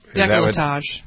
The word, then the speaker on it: décolletage: We've had, what did you, I didn't pronounce it right, décolletage? – Décolletage.